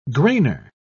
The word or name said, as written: grainer